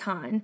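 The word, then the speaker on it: HONNE: I wasn't sure because it's H-O-N-N-E, mm-hmm. all caps. I was like, well, I'm not sure. So we looked it up and it's pronounced Han.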